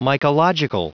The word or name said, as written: mycological